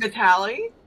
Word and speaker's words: Vitale: Vitale? – Got it.